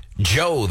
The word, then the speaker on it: Djo: Actor Joe Keery, primarily known for his work on Stranger Things on Netflix. That is him performing as a musician. Joe, the name, D-J-O, the D is silent, end of beginning.